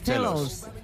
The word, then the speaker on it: Cellos: Northern Cellos. Cellos. Chelos.